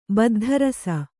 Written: ಬದ್ಧರಸ

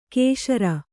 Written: ಕಶೇರು

